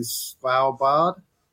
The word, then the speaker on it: Svalbard: About 2 years ago, I went to Svalbard.